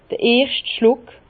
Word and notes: d'r erscht Schluck: Bas-Rhin; Herrlisheim

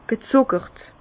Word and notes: gezùckert: Bas-Rhin; Herrlisheim

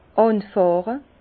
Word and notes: anfàhre: Bas-Rhin; Herrlisheim

